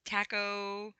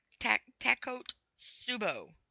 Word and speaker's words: Takotsubo: Clinically, it's called, I'm going to probably butcher this name too, Tacosubo? I know, I totally butchered that name. Okay, we're going to spell it. Takotsubo. Takotsubo.